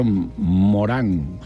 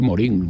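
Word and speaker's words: Morin: decía Edgar Morán, se escribe Morín, Morín o Morán, como si lo quiere pronunciar en francés o lo quiere leer en español.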